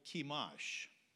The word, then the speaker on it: Chemosh: But here they are worshiping a false god called Chemosh. That's how I pronounce it. C-H-E-M-O-S-H. That's their god.